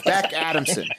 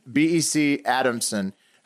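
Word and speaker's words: Bec Adamson: What'd you say her name is? Beck's Bust? What? – Bust Adamson. Beck Adamson. B-E-C. – Tits, madam. – Beck Adamson. B-E-C Adamson.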